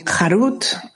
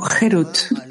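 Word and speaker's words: Herut (חרות): No pronuncien Harut, sino Herut, libertad.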